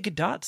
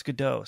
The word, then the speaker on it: Gadot: I think I heard her say Gadot somewhere, and then everybody's like, no, it's Godot.